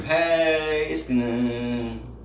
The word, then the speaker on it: Heiskanen: Heiskanen. – Shut up. I'm just kidding. I say Heiskanen because it's spelled kind of like Heinz.